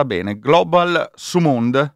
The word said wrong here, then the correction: Sumud: Flotilla. – Sumud, ecco, me lo sono segnato, Sumud, sbagliato.